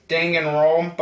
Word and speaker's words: Danganronpa: Danganronpa V3.